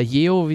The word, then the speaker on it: Yeoh: Geo oder Yeo, wie sie ausgesprochen wird, ist ja mhm.